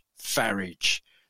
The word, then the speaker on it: Farage: Nigel rhymes with garage Farage.